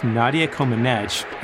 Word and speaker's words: Nadia Comăneci: At the Summer Olympics in 1976, Romanian gymnast Nadia Comăneci, and by the way, that is how you say it, we fact-checked this, her score was perfect.